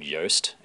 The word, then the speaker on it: Yoast: I think it's called Yoast SEO. Yoast. How would you spell that? Yeah. Uh, y O A S T, like toast oh.